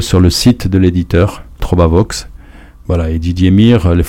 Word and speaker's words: Trobavox: Et puis aussi sur le site de l'éditeur, Trobavox, donc je dis comment ça s'écrit, hein, T-R-O-B-A, plus loin, Vox, V-O-X. Donc c'est Vox, c'est du latin, ça veut dire la voix en latin.